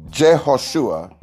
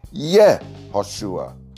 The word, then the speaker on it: Yehoshua: So, Jehoshua is more correctly pronounced as Yehoshua, or often shortened to Yeshua.